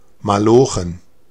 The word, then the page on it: malochen: [maˈloːχn̩]; [maˈlɔχn̩]